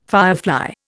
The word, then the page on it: firefly: Indian